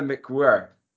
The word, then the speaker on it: McWhirr: Cara McWhirr. Is that how you say it? – Very good. McWhirr. That's how she says it spelled.